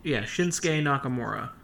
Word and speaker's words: Shinsuke Nakamura: Are we sure that we're saying his name right? – Yes. – Shinsuke Nakamura.